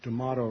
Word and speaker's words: D'Amato: People say D'Amato rhymes with tomato, but I'm from Kansas City. I can't bring myself to say tomato. But it does happen to rhyme with sleazeball obligato.